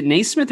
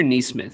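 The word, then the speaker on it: Nesmith: Is it Naismith or Neesmith? Because I want to be saying it right. – Neesmith. – Okay. I keep wanting to say Naismith just because of the Hall of Fame. – Fine, whatever. Yeah, I'm all about Neesmith.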